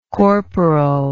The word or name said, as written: corporally